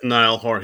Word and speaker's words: Niall Horan: Yeah, yeah, it's Niall Horan.